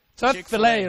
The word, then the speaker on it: Chick-fil-A: Chick-fil-A, right? – Yeah.是福来鸡。